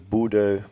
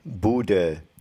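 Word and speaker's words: Bodø: Ik bedoel, laten we even wel wezen dat de Boede... Want zo spreek je natuurlijk uit, hè. – Boede? – Boede.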